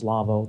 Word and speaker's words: Lawo: Now, it's spelled L-A-W-O. It's a German company. Its pronunciation is Lavo. That's the way it goes. Don't ask me why. It's just that.